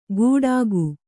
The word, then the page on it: ಗುಡಾಕು: guḍāku